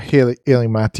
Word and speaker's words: Illimat: And then we played Rush MD and Illimat. Is that how you pronounce that one? – Illimat? Yeah. Illimat, yeah. Yep.